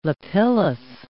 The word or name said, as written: lapillus